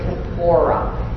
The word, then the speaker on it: kippora: Atonement, the Hebrew word is kippora, K-I-P-P-O-R-A, phonetically, and it means to cover.